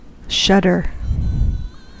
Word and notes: shudder: American English

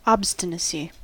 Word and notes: obstinacy: American English

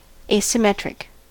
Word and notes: asymmetric: American English

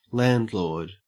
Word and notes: landlord: Australian English

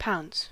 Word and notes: pounce: American English

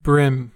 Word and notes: bream: American English

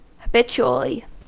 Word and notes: habitually: American English

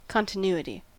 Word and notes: continuity: American English

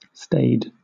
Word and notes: staid: British English

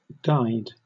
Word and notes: guyed: British English

